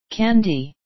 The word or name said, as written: candy